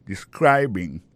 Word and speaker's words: describing: Alors, describing là c'est participe présent verbe to describe. Ça nous kawe là, ça nous constate là, nous ka constate que il y a prononcé moment de describing, accent tombé sous deuxième syllabe là. Ça veut dire mot ça a trois syllabes describing. I'll be talking about describing your job history.